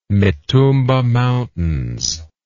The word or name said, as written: mitumba mountains